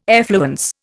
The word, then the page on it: effluence: Indian